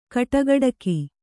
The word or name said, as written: ಕಟುಗೊಡಗಿ